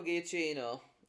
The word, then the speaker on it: Giacchino: Michael Giacchino, unless I'm pronouncing that wrong. – Yeah, so Michael Giacchino.